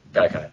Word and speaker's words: Gaikai: Is it Gekai or Gaikai? Somebody please correct me. I keep saying it wrong. – Gaikai, that's the one.